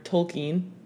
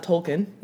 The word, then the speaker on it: Tolkien: I learned throughout this book that it's pronounced J.R.R. Tolkien, not Tolkien.